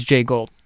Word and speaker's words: Jay Gould: Now this is Jay Gould. So I did get the name correctly.